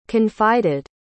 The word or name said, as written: confided